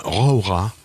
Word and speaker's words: Jauja: On va en Argentine voir le film Raura. Ça s'écrit J-A-U-J-A, Raura.